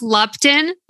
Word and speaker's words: Lupton: It's pronounced Lupton.